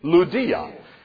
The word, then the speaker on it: Lydia: Her name is actually pronounced Lydia. Can you say it with me? Lydia.